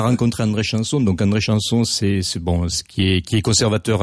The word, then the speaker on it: Chamson: Comment vous dites Chanson, Chamson, Chanson? – On dit André Chanson.